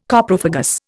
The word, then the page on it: Coprophagous: Indian